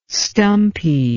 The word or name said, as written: stumpy